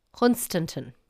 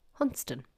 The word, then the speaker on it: Hunstanton: So we have a town in Norfolk, which I would look at the spelling and say Hunstanton. But no, it's actually pronounced Hunston. Don't know where the the stan went, it just disappeared.